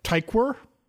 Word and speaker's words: Tykwer: How do you pronounce this last name? Ty- Tykwer. Tom Tykwer.